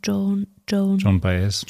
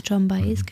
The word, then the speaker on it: Joan Baez: Ich kann sie nicht aussprechen, Joan Baez. – Joan Baez, genau.